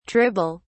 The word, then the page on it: tribble: British English